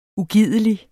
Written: ugidelig

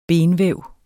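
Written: BENVÆV

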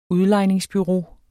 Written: udlejningsbureau

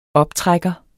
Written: optrækker

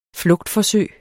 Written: flugtforsøg

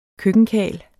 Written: køkkenkarl

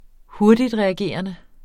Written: hurtigtreagerende

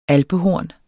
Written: alpehorn

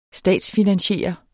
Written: statsfinansiere

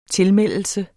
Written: tilmeldelse

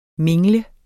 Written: mingle

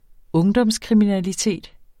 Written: ungdomskriminalitet

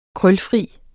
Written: krølfri

